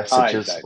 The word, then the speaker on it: Sitges: An hour outside of Barcelona into what essentially is kind of like the beachfront and hills of a place called Sitges. Yeah, is that how you say it? Yeah, Sitges.